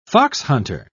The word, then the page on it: foxhunter: British English